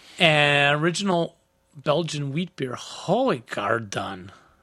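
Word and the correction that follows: Hoegaarden: I think it's pronounced Hoo Garden.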